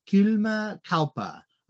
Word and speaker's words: Kylmä kauppa: It is called Kylmä kauppa, a cold trade in most of the Finnish translations. – Yes, that's a very, very good pronunciation.